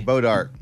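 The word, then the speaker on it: Bois d'Arc: Bodark. – Bodark. – It's pronounced Bodark, but it's spelled Boys Dark.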